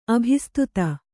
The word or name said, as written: ಅಭಿಸ್ತುತಿ